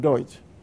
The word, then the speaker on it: Duch: So Deutsch is D-U-C-H, but it's pronounced Deutsch.